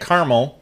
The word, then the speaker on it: Carmel: And I say it Carmel because it's spelled, for all you fucking idiots, it's spelled C-A-R-M-E-L. – Boom. – Not C-A-R-A-M-E-L, which you fucking goddamn morons.